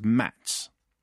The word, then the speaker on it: Mads: You pronounce the K and T in Knut, and the D in Mads is pronounced as a T. Someone has been lying to you, Mark. So Knut is Knut, and Mads is Mats.